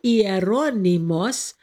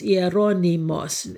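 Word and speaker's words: Hieronymus: So his name in Greek is spelled with an I. Hieronymos is how they pronounce it. And so you will sometimes find it under I or under H, really under H, H-I-E-R-O-N-Y-M-U-S. Hieronymos is how they will say Jerome.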